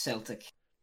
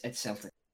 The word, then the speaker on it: Celtic: Well, it's first of all pronounced Celtic, and it's Celtic.